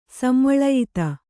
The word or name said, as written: ಸಂವಳಯಿತ